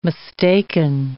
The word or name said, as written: mistaken